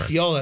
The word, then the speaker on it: Osceola: Osceola, that's how you say it.